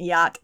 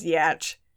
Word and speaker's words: yacht: which is Sticky and 15 Cent pronounced yacht as yatch. Yeah. Or yatched, actually. Yatched.